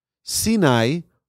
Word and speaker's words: Sinai: Sinai rhymes with the word Sina. Sinai is the Sinai desert, but it also rhymes with the word in Hebrew of Sina, which is hatred.